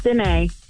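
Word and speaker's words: Danee: Danae.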